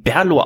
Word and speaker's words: BRLO: Es gibt von unseren Freunden von Berlo, B-R-L-O wird's geschrieben, Berlo ausgesprochen, das ist der altslawische Name von Berlin.